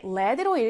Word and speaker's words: lead: 우리 LEAD가 lead로 읽힐 때는 동사, 이끌다, 이런 뜻이고요. 이렇게 led로 읽힐 때는 납이라는 뜻으로 알아두시면 되겠습니다. 철자는 똑같은데 발음이 달라지는 특이한 경우죠.